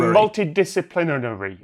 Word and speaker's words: multidisciplinary: You're putting the emphasis on the C. The emphasis should be on the N. So you both said multi-discipline... You basically said scenery. – Yeah. – It should be multidisciplinary.